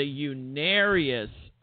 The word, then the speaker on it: Unarius: Well, maybe you should join the Uranus Academy of what, Stephen? Oh, I'm mispronouncing the name. I'm sorry. The Unarius Academy of Science, which, oh my God.